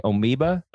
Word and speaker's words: Amoeba: So I was at, I can never say it right. Adam always corrects me. Amoeba. Records, is that what it is? Yes. Yep. Said it right. First time ever.